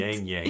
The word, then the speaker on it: ylang ylang: Middle notes are frangipani, tuberose, ylang ylang, as I like to call it. Ylang, that L is silent. – Yang yang.